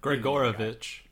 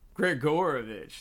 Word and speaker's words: Grigorovich: But, you know, we were talking about Gringorovich. How do you say it? – Grigorovich. – Grigorovich. There you go.